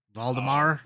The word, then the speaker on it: Valdemar: I think my favorite is the case of Mr. Vladimir. How do you say it? – Valdemar. – Valdemar, yeah.